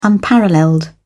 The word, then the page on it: unparalleled: British English